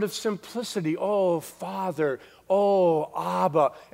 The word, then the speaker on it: Abba: The Aramaic word was the word Abba. It's spelled A-B-B-A. And when Jesus uttered the words, this is actually what he would say. This is actually how it would sound. He would say, Abba, Abba.